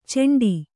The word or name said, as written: ಚೆಂಡಿ